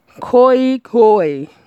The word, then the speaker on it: Khoi Khoi: Well, what is called Reunion is Khoi Khoi. Khoi Khoi. Pronounce Khoi Khoi. Spell K-H-O-I. K-H-O-I. No pronunciation, how it pronounces K. O E K. O I. Koi Hoi.